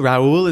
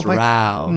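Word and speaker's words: Raoul: Yeah, you actually pronounce it Raul. Raul is the Spanish pronunciation. We're in France, so it's Raul.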